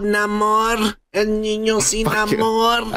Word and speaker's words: Namor: Namor. – He's still white. – Namor. Say it right. – That's fucking bullshit. – Yeah. Say it right. – It's Namor, motherfucker.